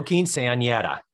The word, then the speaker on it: quinceañera: quinceañera, the... – Oh, quinceañera.